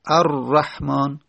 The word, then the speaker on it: الرحمن: اگر بگیم الر الرحمن الرحمن، اما لام تبدیل کردیم به را و را رو در را ادغام کردیم، پس شد الرحمن. پس را در تلفظ زدن دو تا شد.